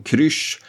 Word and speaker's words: Kryzz: Han hade även några år tidigare skapat programmet Rassel, också då ett program med massa Z i eftersom krysch, och det kanske ni hör på hur jag uttalade, stavades K-R-Y-Z-Z och Rassel hade ju då två Z i sig.